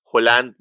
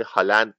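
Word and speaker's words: Haaland: ببین مثلا الان چند تا بازیگر، مثلا چند تا بازیکن فوتبال جدید اومدن، یه بازیکنی هست حالا که احتمالا تلفظش باید هلند باشه ولی هالند مثلا تلفظ میکنن در خارج از نروژ،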